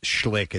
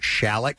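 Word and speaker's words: Schalick: I was, people were saying that the school in New Jersey is not pronounced Schlick. It's pronounced Shalik. – Shalik. – It's spelled S-C-H-L-I-C-K, right?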